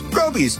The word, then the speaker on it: Groby's: Groby's, where the accent is on grow.